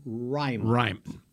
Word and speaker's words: Raimann: I haven't figured out how you pronounce his name. – Yeah, it is Bernard Raimann. – Bernard Raimann. – Bernhard. Yeah, Burr. B-E-R-R.